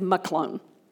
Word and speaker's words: Mahlon: When you look at the book of Ruth later, you're going to look at that and go, she mispronounced that. It actually is Mahlon. Mahlon.